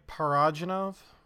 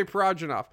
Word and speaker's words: Parajanov: No, Sergei Parvov. We're done talking about Tarkovsky. – Sergei Parajanov? – Yes, you said it right. Sergei Parajanov.